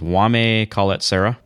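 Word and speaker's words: Jaume Collet-Serra: how would you say this, Wame Coletzera? – Bravo. That's exactly what I was going to say.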